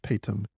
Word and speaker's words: Paytm: I think it's called Paytm, or pronounced Paytm, P-A-Y-T-M.